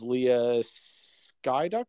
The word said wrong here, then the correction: Seydoux: How do you say it? – Seydoux. – Oh, jeez. – It's okay. French, man. Come on. – Seydoux.